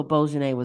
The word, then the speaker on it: bourguignon: And one time I had buffalo bourguignon.